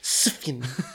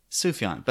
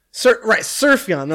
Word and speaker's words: Sufjan: Sofian, or what does she call him at the Oscars? – Sufjan. – How is his name actually pronounced? Is she right? – Sufjan. But she said surf. – Surfion, that's what it was.